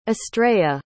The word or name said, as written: astraea